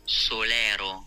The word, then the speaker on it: Solaro: Here's the pronunciation. – Solaro. – Solaro. – That's how you pronounce that word. So it's an Italian word.